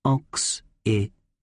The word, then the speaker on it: Occitania: That CC is pronounced X, making it Occitania.